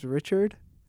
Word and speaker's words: Richard: My name's Richard. – So how did you say it? So I got two R's. So what would you say, Witchward? – Yeah, something like that.